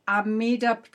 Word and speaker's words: Amida: So, David, is this practice the Amida Pure Land practice? Did I say that correctly? – Yes.